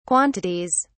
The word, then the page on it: quantities: British English